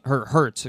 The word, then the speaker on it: Hurts: And that's what I was looking for, really, for in terms of Jalen Hurts, excuse me. Gotta pronounce the H.